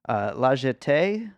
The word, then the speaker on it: La Jetée: La Jetée, is that how you say it? – Yes, your French is immaculate.